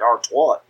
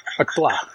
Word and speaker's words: Artois: How hard is it to say Artois? – Sounds like Artois. – Artois. It's French, bitch.